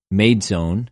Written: μειζων

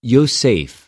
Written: Ἰωσηφ

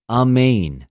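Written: ἀμην

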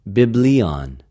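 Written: βιβλιον

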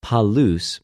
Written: πολυς

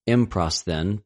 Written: ἐμπροσθεν

